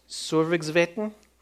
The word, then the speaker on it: Sørvágsvatn: This is Sørvágsvatn, the largest lake in the Faroe Islands.